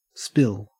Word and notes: spill: Australian English

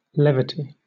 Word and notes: levity: British English

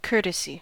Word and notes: courtesy: American English

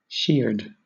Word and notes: sheared: British English

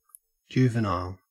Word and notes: juvenile: Australian English

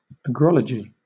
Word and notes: agrology: British English

